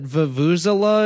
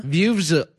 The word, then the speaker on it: Vuvuzela: First thing I need to clarify, you said Vuvuzela is how you pronounced it? – Vuvuzela? – Vuvuzela. Okay.